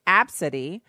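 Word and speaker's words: Abcde: Now, this chick actually has, she said it's pronounced Absidy,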